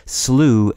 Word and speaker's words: slough: These include slew as the past tense of the verb to slay, slew as a spelling variant of slough, a word which is also commonly pronounced slew. It's spelled S L O U G H and it means swamp,